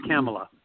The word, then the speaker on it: Kamala: It rhymes with Pamela, doesn't it? So it's Kamala.